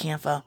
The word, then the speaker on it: Campho: It's spelled Campo. So I think it's Campo. It's spelled C-A-M-P-H-O.